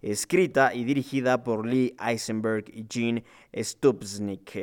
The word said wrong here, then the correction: Stupnitsky: Eh, o bueno, eh, a ver, si lo pronunciamos de forma correcta sería Stupnitsky. Así sería el nombre de este señor.